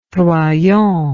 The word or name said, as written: troyon